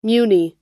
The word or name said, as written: muni